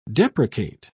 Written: deprecate

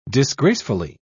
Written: disgracefully

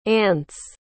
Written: Ance

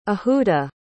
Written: ahuda

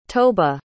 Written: toba

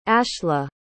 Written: ashla

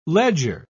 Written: ledger

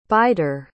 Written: bider

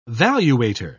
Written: valuator

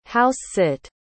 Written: house-sit

